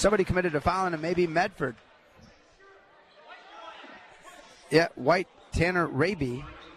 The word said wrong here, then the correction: Hraby: Tanner Harabi, I'm sorry. Harabi, H-R-A-B-Y, Harabi.